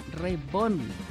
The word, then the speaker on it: Reborn: Reborn, así se pronuncia.